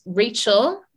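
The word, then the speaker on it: Rachael: Well, the name of the YouTube channel is Rachel. My name is spelled with an extra A, R-A-C-H-A-E-L, Raw Food.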